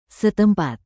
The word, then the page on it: setempat: Indonesian